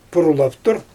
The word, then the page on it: purrlsaftur: Xyzyl